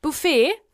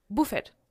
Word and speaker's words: Buffet: Zum Beispiel in der Gastronomie sagt man nicht Buffet, sondern Buffet. – Ja, oder? – Und Dessert. – Genau. – Da wird immer die erste Silbe betont.